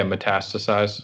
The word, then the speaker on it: metastasize: Can you say that word for me? – Yeah, metastasize. – Metastasizes. Yes, that's the word.